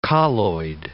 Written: colloid

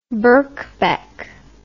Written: Birkbeck College

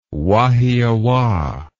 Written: Wahiawa

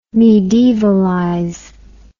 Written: medievalize

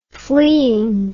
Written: fleein

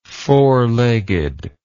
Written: four-legged

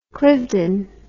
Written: cliveden

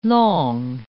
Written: langue

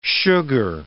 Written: sugar

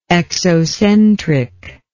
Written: exocentric

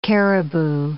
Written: caribou

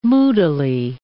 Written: moodily

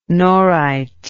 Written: norite

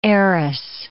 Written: heiress

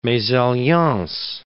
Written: mesalliance

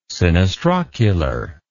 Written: sinistrocular

